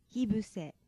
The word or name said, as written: hibuse